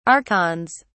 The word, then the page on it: archons: British English